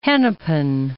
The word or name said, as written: Hennepin